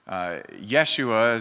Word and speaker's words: Yeshua: Yeshua is usually how it would be pronounced in Aramaic, but in Hebrew, this is actually a name similar to what we see as Joshua, Yehoshua.